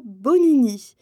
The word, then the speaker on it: Bonini: Moi, je m'appelle Marina Bonini. B-O-N-I-N-I.